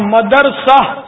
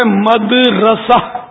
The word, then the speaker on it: مدرسہ: ہاں مدرسہ یہ غلط العوام ہے, اصل اس کا تلفظ ہے مدرسہ, میم دال زبر مد را زبر را, را زبر را اور سین آ زبر سہ, اردو کے حساب سے ہجیز کی یہ بنے گی.